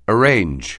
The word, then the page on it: arrange: anglais